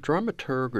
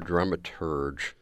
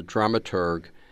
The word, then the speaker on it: dramaturg: Is it dramaturg or dramaturge? – We dramaturgs like to do the hard G at the end.